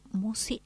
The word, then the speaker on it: Musci: El canal lleva mi nombre, Marisa Musi. – ¿Cómo se escribe Musi? – Musi, M-U-S-C-I latina.